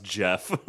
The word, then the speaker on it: Geoff: I did that with a comic writer for a while where I was like, oh, Geoff, that's an interesting name, but it's like it's pronounced Jeff.